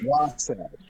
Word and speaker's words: Wasatch: Am I saying that right? Wasatch or Wasatch? Wasatch? – Wasatch. Wasatch. Yeah. Wasatch Wasatch Pop. It's a mountain range here in Utah, so Wasatch Pop.